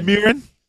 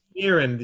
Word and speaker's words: Mirren: I cannot allow you to go on mispronouncing my girl's name. It's Helen Mirren. – Mirren. – Mirren. – You're I'm adding dumb. syllables right and left, like you were, uh, uh, you know, a Russian diplomat speaking English for the first time.